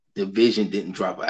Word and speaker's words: DVSN: It's Division.